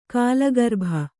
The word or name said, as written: ಕಾಲಗರ್ಭ